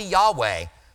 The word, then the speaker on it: Yahweh: Sorry, I'm picking on the Jehovah Witnesses so much today, but there's no J sound in Hebrew. It's a Y. And probably Yahweh, the Hebrew theologians believe that that's the pronunciation.